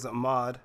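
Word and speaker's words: Ahmaud: Ahmed Arbery. – Yeah, I think it's Ahmad. – Ahmad, sorry.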